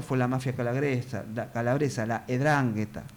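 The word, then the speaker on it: 'Ndrangheta: Me dijeron que es griego y se pronuncia como E, por eso, Edrangheta.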